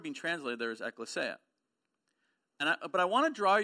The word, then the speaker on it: ekklesia: It is pronounced ekklesia. It is not ecclesia. John Papp likes to pronounce it ecclesia just to drive me crazy.